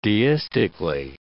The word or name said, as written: deistically